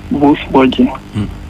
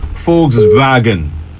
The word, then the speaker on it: Volkswagen: It's a German brand. And it's called Volkswagen. Volkswagen. – So, Volkswagen. When you see V in Germany, is usually pronounced as F. Mm. And when you see W, is usually pronounced as V. It's Volkswagen. Volkswagen.